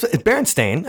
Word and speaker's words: Berenstain: Well, it's Berenstain. I mean, because it's stain. – It's the way it looks. – But because if we said it different, if we said it like Shel Silverstein. – Yeah. – Or Stein. – Or Frankenstein. Then it would be with an E. But Berenstain obviously has an A.